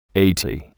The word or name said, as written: Eighty